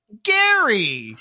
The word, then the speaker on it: Gaeryie: I spelled his name G-A-E-R-Y-I-E. And then we're like, how do you pronounce that? And a townsperson was like, Gary!